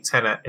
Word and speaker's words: Tenet: Did I say that right? – Tenet.